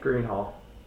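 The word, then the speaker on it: Greenhalgh: The GH is silent. – Yeah, that's why. Greenhall. H-A-L-G-H.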